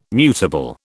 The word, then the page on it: mutable: British English